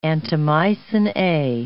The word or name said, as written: antimycin A